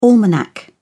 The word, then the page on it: almanac: British English